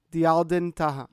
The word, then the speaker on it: Diaaeldin Taha: I'm here with Frank CiFuentes, and I'm with, I want to say this correctly, Diaaeldin Taha.